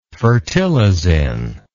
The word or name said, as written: fertilizin